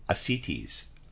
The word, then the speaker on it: ascites: When the patient came to us, she had a gravitation abscess in the right thigh, bloated abdomen with slight ascites. Reader's aside, I'm pronouncing that. It's A-S-C-I-T-E-S. Forgive me, I'm pronouncing that ascites.